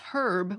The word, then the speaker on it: herb: One might point out that standard British English says herb with an H sound, but a number of other British varieties and most American English varieties don't, suggesting that the H pronunciation wasn't the typical one before the settlement of the American colonies.